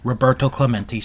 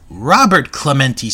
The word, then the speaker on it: Roberto Clemente: Because this match is held in Puerto Rico, and it's held in Roberto Clemente Stadium. Well, Lord Alfred Hayes pronounces it as Robert Clemente Stadium. Oops.